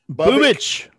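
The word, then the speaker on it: Bubic: So, but Bubik, Bubich, let's go Bubik. Is that what it is? – Bubich, Bubich, Bubich. All right, got it.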